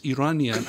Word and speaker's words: Iranian: And by the way, it's the correct pronunciation is Iranian, and I'm not.